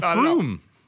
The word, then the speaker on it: Frome: F-R-O-M-E. It's like yeah. Rome with an F at the beginning. – Oh, Froom. But it's Froom. – But it's Froom. Froom.